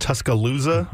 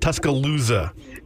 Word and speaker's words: Tuscaloosa: You are a way better sports professional than I am, but I believe it's pronounced Tuscaloosa. – Oh, my God. – I believe you're hitting the emphasis a little bit different. Tuscaloosa.